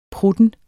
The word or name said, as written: prutten